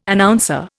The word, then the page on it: Announcer: Indian